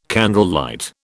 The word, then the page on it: candlelight: British English